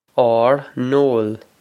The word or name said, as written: ár n-ól